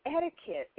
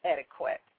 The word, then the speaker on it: etiquette: And that's because it's spelled E-T-I-Q-U-E-T-T-E. And, of course, we all know that the Q makes the qua sound. And to help you to remember that this word, the Q actually adopts the K sound, is because, remember, we just said that the word itself comes from the word ticket. So it's pronounced etiquette.